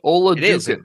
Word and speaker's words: Oladokun: Oladokun. It's a U, I think. Oladokun. – It is a U. I looked it up last night, and your emphasis on the syllables is correct.